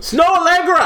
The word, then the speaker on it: Snoh Aalegra: But this pie I want to eat is, how do you say her name? – Fam. – I can't say her. I see it and it's just. – Oh man, Snow. – Snow Allegra. – Allegra, there you go.